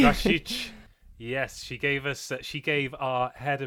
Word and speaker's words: Rašić: Yeah, to be fair, I would have pronounced it Rašić, and I think I have my whole career, but yes, now I learned it's Rašić, Rašić, Rašić.